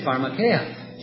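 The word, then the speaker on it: pharmakeia (φαρμακεία): Okay, pharmakeia.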